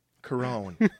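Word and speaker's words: Korine: And Harmony, how do you say Harmony's last name again? Carone. – Carone's good. – Harmony Corona.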